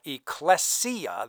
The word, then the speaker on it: ecclesia: And so when Jesus said, "Upon this rock I will build my ecclesia," that's the correct pronunciation in Greek, he was saying, "I will build my governmental structure."